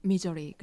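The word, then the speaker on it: Missouri: 음, 어, 이렇게 알고 있는데 어, 이 미, 미주리를 영어로 발음하는 미저리 그래요.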